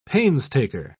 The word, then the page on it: painstaker: British English